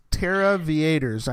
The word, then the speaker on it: Terraviators: Terraviators, not Terraviators or anything else. It's Terraviators. I think it's like aviators, but they're cachers, so they're doing Terra, so it's Terraviators.